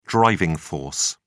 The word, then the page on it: driving force: British English